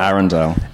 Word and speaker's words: Arundel: Now, is it Arundel or is it Arundel? – Arundel.